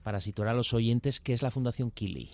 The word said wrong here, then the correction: Qili: Eh, bueno, eh, corrección pequeñita, se pronuncia Chili,